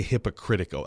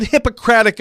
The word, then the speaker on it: Hippocratic: Ty says, I did not say hypocritical. I said Hippocratic Oath, Ty. – You did say Hippocratic, so you're good. – Hippocratic. – Yeah, you said it right. – Yeah, Ty thinks I said hypocritic.